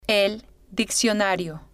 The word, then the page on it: el diccionario: female